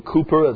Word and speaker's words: Cowper: That hymn is by William Cowper. That's how you pronounce his last name.